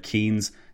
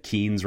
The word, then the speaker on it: Keynes: I said John Maynard Keynes several times.